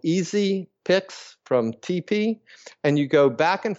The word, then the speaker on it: TePe: But there is a little device by a company called TP, and it's spelled T-E-P-E, and it's available online.